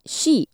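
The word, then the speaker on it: shi (四): 4 is shi in Japanese, and the kanji for death is pronounced the same way.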